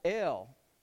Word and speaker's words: El: Because there would be a little vowel point here for an E, so the Aleph is a silent letter and is dictated the sound by the vowel point, and this is the Lamed or the L, and so you have El, which is God.